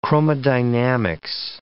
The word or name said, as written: chromodynamics